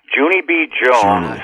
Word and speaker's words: Junie B. Jones: Well, anyways, let's talk about Hooney B. Jones. Is that how it's pronounced, by the way? – Junie B. Jones. Okay.